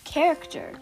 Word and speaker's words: character: Of character. Because of character. Mm-hmm. It's spelled C-H, but it's pronounced just K.